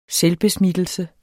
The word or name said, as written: SELVBESMITTELSE